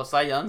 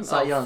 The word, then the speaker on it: Cy Young: I don't know how to pronounce his first name. Cy? C.Y. Young? – Oh, Cy Young? – Cy Young.